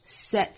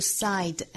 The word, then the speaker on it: said: Just 后面 t 略掉 Because they said so. Said 不是 side, S-A-I-D, 注意是单元音 A.